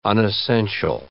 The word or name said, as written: unessential